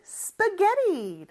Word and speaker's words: spaghetti: 스파게티의 영어 발음은 spaghetti입니다. 그래서 영어는 높낮이가 있다고 했잖아요. spa 내려갔다가 getty 이렇게 발음해줘야 돼요. 그리고 마지막 요 t, 물론 t가 두 개지만 영어는 보통 자음이 두 개 있으면 하나만 발음해주거든요. 그래서 하나 있다라고 가정을 했을 때 T 발음은 모음 소리 사이에 껴서 이것도 real처럼 발음이 돼요. 아주 짧게 해줘야 돼요, 대신. spaghetti, spaghetti 이렇게 발음합니다.